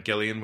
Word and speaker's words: Gillian: Well, I know you've been excited about this because I know you've mentioned Gillian with a hard G